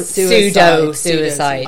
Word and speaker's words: Pseuicide: But this how to deal this suicide Munchausen by proxy, and it's spelled very cleverly PSEUICIDE. So pseudo suicide.